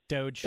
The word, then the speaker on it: Doge: is it pronounced Doge? – Doge.